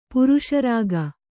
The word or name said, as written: ಪುರುಷರಾಗ